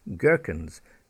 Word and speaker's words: gherkins: Ghastly, that was a ghastly accident last week, ghastly, G-H-A-S-T-L-Y, not G-A-S, ghastly. And I like to eat gherkins on my burgers, who doesn't? Gherkins, G-H-E-R-K-I-N-S, gherkins, not G-E-R.